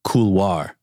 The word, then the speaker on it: couloir: I would love to hear about bloody cooler. Couloir. Couloir. Couloir. Couloir. – it's the, it's the French word couloir.